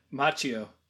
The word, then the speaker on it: Macchio: Okay. Fine. We'll do it your way. Macchio. Ralph Macchio.